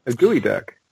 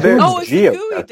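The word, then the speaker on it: geoduck: Is that a gooey duck? – Yes.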